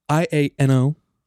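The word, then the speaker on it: Galliano: However Italians pronounce it. – It is Gagliano.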